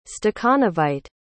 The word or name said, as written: stakhanovite